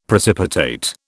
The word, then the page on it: precipitate: British English